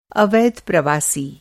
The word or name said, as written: अवैध